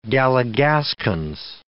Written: galligaskins

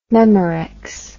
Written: memorex

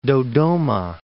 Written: Dodoma

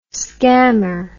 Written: scammer

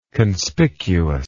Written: conspicuity

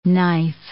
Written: knife

